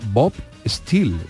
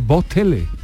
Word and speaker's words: Steele: Eran películas de un, de un vaquero americano que se llamaba Bob Steele, como Bob, Bob Acero, Bob Steel, pero eh, ellos lo pronunciaban como las películas de Bob Tele.